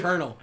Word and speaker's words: colonel: It's like colonel. Colonel. He no, goes, he's... No, yeah, it's spelled colonel. He's like, none of these letters make that sound. C-O-L-O-N-E-L. How the fuck do you get colonel out of that?